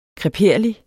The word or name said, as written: kreperlig